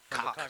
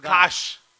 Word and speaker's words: Koch: K-O-C-H. – K-O-C-H. – That's cock. That's cock. – From Oshkosh. – That's Kosh. That's cock, but from the Oshkosh H is silent.